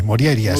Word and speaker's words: Morjaria: Bueno, la J normalmente se pronuncia como I. Morjaria sería. – Morjaria.